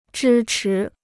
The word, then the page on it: 支持: zhī chí